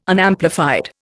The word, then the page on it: Unamplified: Indian